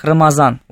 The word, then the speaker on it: Рамазан: В арабском языке произносится как Рамадан. Прям с таким твердым. Да, Рамадан. У персов оно произносится как Рамазан. Вот эту букву они произносят как букву З.